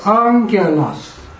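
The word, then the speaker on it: angelos (ἄγγελος): 所以这一个前面这一个要念 ang 的音，所以怎么念呢？ angelos， angelos，因为这个有两个歌，就是任何一个在伽马之前有科或者是 Kappa 或者说 key，要念 ng 的音，ng 的音。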